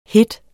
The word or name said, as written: hede